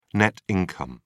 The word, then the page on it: net income: British English